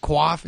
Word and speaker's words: coif: He's like, you hide that coif. Is that how you say it? Coif? – Yeah.